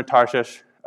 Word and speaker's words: Tarshish: So when he said, "I'm actually going to go to a place called Tarshish," it's pronounced Tarshish, I don't have a speech impediment.